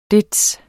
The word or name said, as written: det